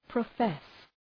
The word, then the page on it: profess: prə'fes